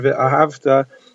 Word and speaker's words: ve'ahavta: So, for example, the word ve'ahavta, if you say ve'ahavta, it means you should have loved. Ve'ahavta means you should love, like a tzivya command. Obviously, you have to pronounce ve'ahavta.